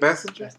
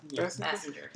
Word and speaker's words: Basinger: Kim Basinger. How do you say that? Basinger. – Basinger.